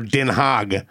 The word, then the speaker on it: Den Haag: Rotterdam, Amsterdam, pronounced the same, but like what it shows on the maps to Americans, it's the Hague is D-E-N-H-A-A-G over there, Den Haag.